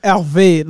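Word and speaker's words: Hervé: Herve Levine. – Herve Levine. – Yeah, so it's a French name.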